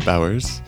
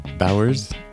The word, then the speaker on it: Bowers: So my name is Brent Bowers. Brent, B-R-E-N-T, Bowers, B-O-W-E-R-S.